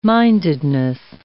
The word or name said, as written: mindedness